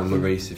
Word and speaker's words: Lloris: Right, so we played 4-2-3-1 that day. So in goal was Hugo Lloris. Right back, or Lloris, if you're saying it correctly.